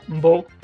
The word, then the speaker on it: Mbow: It resembles the pronunciation of my name, you know, Bo, like, so, I don't know, something that stuck with me, you know, when I was younger, I used to answer like that.